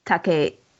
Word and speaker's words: Takei: It's Takei. Takei is... – Takei, yes. – Yes. – That's what George he has Takei. Said. I've heard him in interviews, and it is Takei.